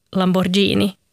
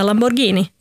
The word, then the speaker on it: Lamborghini: Och samtidigt skulle jag själv aldrig någonsin drömma om att säga Lamborghini. Det heter Lamborghini. Mm. Och det ser man för det finns ett H efter G, vilket betyder att det ska uttalas som ett G.